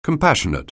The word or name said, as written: compassionate